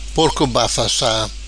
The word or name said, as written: Porco bafassa